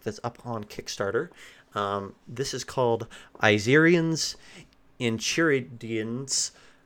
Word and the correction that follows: Enchiridion: Hope I said that right. – I think it's Enchiridion.